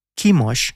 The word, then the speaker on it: Chemosh: Chemosh, que en inglés se escribe Chemosh y en español se dice Chemos, era el dios de los moabitas, un reino antiguo que quedaba junto al Mar Muerto, donde hoy en día queda Jordania.